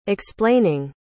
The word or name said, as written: explaining